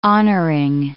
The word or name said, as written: honoring